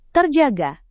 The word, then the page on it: terjaga: indonesian